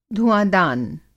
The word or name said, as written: धुआँदान